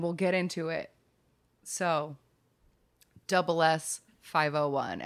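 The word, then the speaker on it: SS501: And it is double S. – It is pronounced double S. Okay, I was just reading it as SS 501. – I know, I'm sure I have said that on the show before. I have said SS 501.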